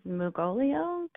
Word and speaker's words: Mugolio: The best I could find is Mugolio, kind of like the Mugo pine or Mugo pine.